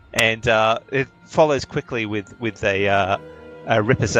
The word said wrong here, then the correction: riposte: How do you pronounce that? – Riposte. – Riposte. Thank you.